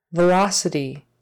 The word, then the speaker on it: velocity: Make sure that first V is a real V, not a B. We want to make it soft. Your teeth are on your lip, like a, an F that is voiced. Velocity. That C in the middle is an S. Velocity. Velocity. Syllable stress on the second syllable.